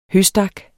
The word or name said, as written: høstak